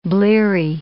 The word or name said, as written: bleary